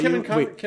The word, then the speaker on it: Kevin Conroy: Did I say Kevin Conroy? – Yeah, you said it right.